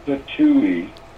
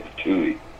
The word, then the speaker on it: Pitohui: The hooded patooey, it's spelled P-I-T-O-H-U-I. – Mm-hmm. – I'm going to say patooey.